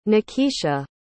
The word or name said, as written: nakisha